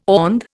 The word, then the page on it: OND: Indian